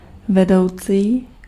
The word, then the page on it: vedoucí: /vɛdɔʊ̯tsiː/